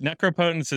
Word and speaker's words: Necropotence: No, Necropotence. Necropotence is the common pronunciation.